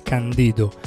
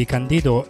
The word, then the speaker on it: Candido: Voglio fare un altro omaggio a un altro dei miei artisti preferiti dell'epoca, Candido Camero Guerra, conosciuto semplicemente come Candido. L'accento va sulla I perché non è una parola anglofona, bensì lui è di origine cubana, quindi questo spiega tutto.